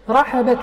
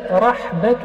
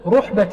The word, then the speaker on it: رحبة: رحبة المسجد هكذا ضبطها بفتح الراء وفتح الحاء، رحبة المسجد، أو رحبة المسجد بفتح الراء وسكون الحاء، ولكن الأكثرون على تحريك الحاء، بعضكم ينطقها رحبة المسجد وهذا ليس بصواب، إنما الصواب رحبة المسجد أو رحبة المسجد بفتح الراء ثم الحاء، إما محركة عند الجمهور أو مسكنة.